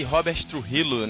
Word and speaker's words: Robert Trujillo: É isso aí, vamos contar a história da, da banda formada por James Hetfield, Lars Ulrich, Kirk Hammett e Robert Trujillo, né? Isso aí, Guilherme, falei certo? – É, exatamente.